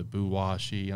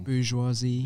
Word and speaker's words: bourgeoisie: It's a form of the bourgeoisie. I'm saying that wrong. – Bourgeoisie. – There, bourgeoisie. There we go.